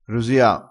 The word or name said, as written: rosia